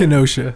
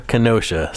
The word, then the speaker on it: Kenosha: Kenesha, Kenesha, Kenesha, Wisconsin. Is that how you say it? Kenosha. – Kenosha. Sorry, I don't.